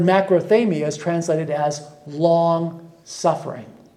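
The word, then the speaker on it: macrothamia (μακροθυμία): And I took Greek, and my Greek isn't all that good, but I can pronounce this word as macrothamia.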